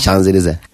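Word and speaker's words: Champs-Élysées: Şamzelize değil mi? Şanzelize mi o? – Şanzelize.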